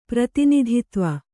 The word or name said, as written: ಪ್ರತಿನಿಧಿತ್ವ